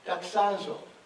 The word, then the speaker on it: doxazo: The Greek word to glorify is doxazo. And you're thinking, you're pronouncing that wrong. But Google said to pronounce it that way. So that's what I'm doing.